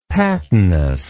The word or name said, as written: patinous